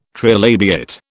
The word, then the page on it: trilabiate: British English